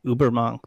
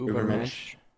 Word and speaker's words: Übermensch: In this chapter, he brings up Nietzsche, I believe, and the idea of the Übermensch, the Superman. I think I mispronounced that. – The Übermensch.